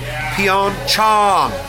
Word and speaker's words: Pyeongchang: Asian American Journalists Association says the South Korean city is pronounced Pyeongchang. Chong, like a long A-ah sort of sound, like the ah you make at the doctor's office.